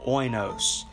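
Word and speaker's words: oinos (οἶνος): Now, when you look at that, and when you look at the translation of the word wine there, that word comes from a word called, or pronounced rather, oinos. Now, oinos is a fermented wine.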